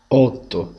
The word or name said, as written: 8